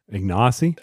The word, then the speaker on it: Ignacy: Yeah, and I think we've been saying the first name wrong by what she said. – Ignacy? – The way she said the first name is not the way we say it. So now I feel bad because we've been saying it wrong for all these years.